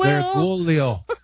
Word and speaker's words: Bergoglio: I'm looking at it right here, and it's supposed to be pronounced Bergoglio. Bergoglio. – Bergoglio? – Yeah, Bergoglio. – Bergoglio. – Yeah, like goal. Bergoglio. Like a goal in a G-O-A-L. Goal.